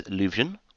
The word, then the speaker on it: Leuvsion: My Miiverse name is Luvsion, which is L-E-U-V-S-I-O-N. Harrison, what's your Miiverse handle? – Mine is Hybrid Meta, and it's spelled H-Y-B-R-I-D as in dog, and M-E-T-A, all one word.